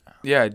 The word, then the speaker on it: Ye: Yeah, did you... Okay, we were saying Ye before, weren't we? And then I've only recently heard on several other podcasts that it's actually pronounced Ye.